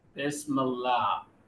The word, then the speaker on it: Bismillah: Bismillah. – Okay, there's a little bit of that extra la in that H at the end.